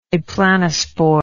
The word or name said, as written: aplanospore